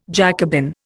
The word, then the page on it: Jacobin: Indian